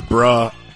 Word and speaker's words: bruh: Because bruh rhymes with cadabra.